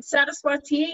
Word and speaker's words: Saraswati: In Hindu mythology, the goddess Saraswati. I don't know if I pronounced it right. – Saraswati, yeah. That's a she's on a lotus.